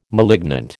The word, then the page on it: malignant: American English